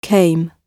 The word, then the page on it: came: British English